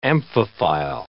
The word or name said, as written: amphiphile